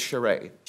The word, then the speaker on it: Charest: and is it pronounced Mr. Charis? – My understanding is Charest.